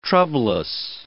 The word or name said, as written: troublous